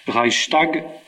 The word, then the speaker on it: Reichstag: Cette Diète était composée de trois collèges, Reichstag, c'est bien ça, je le prononce bien.